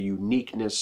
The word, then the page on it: uniqueness: American English